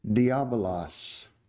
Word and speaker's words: diabolos: The Greek term that's translated devil is pronounced something like, this will be familiar, diabolos.